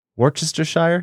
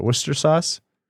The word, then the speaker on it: Worcestershire: Wort, Wor- how do you pronounce it? Worcester sauce? Worcestershire? It's not Worcestershire, but Worcester sauce.